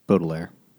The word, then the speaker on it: Baudelaire: Baudelaire.